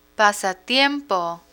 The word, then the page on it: pasatiempo: Spanish